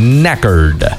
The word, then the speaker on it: knackered: Knackered. – Knackered. – Wait a minute. The K is silent.